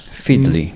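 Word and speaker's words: Feedly: Ten Fedly, nie jestem pewien, jak to się wymawia. W każdym razie ta strona całkiem nieźle agreguje te RSS-y, także... – Fedly, bo tak. To od feed, czyli właśnie no, feed to jest...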